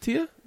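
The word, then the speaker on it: tía: No, how do you pronounce Zia? Tito. Tita. In Spanish. – Tia? – Tim Chung, Spanish. – My Tia. – Is it Tia? – Yeah, okay.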